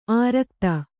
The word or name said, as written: ಆರಾಘಾತ